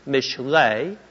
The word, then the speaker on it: mishlei: The word proverbs, pronounced in Hebrew, mishlei, means basically to draw comparisons, similitude, likeness.